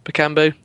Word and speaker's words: Bakambu: Bakambu, that's how you say it.